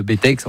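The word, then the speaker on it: Bettex: Alors juste au-dessus de Saint-Gervais, où effectivement, le Bétex. Alors le Bétex, certains disent, prononcent pas le X. Le Bétex. Enfin, il y a deux écoles, mais en tout cas une seule école de ski là-bas, et c'est là-bas où je suis allé.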